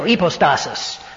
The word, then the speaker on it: hypostasis: I think I pointed out to you before leaving that that word substance is hypostasis, or as the modern Greeks would say, and I would have to say in my old congregation in Glendale, hypostasis, which is the proper pronunciation.